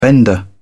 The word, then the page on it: bender: British English